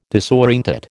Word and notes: disorientate: American English

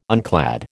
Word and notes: unclad: American English

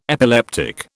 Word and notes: epileptic: British English

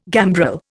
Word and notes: gambrel: Indian

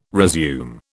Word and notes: resume: British English

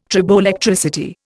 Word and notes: triboelectricity: Indian

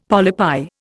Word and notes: polypi: Indian

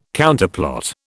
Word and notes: Counterplot: British English

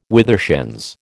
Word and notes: withershins: American English

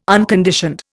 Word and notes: unconditioned: Indian

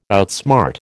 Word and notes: outsmart: American English

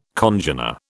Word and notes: congener: British English

